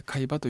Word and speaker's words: 海馬: 記憶ってのは実はご存じだと思いますが、海馬というね、海の馬と書いて海馬という場所が、視力中心にその機能をしているわけですが、海馬という場所は実は脳みその中でも障害を受けやすい場所なんですね。